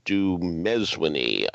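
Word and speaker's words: Dumezweni: Noma Dumezwini, I've been told, the accents on the second syllable.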